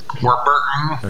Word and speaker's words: Warburton: Warburton.